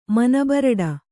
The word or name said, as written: ಮನಬರಡ